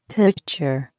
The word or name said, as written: pitcher